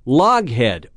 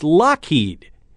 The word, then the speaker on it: Loughead: The brother's original last name was spelled L-O-U-G-H-E-A-D. So folks would say Loghead or Loafhead. Not too impressive. The correct way to say it is Lockheed.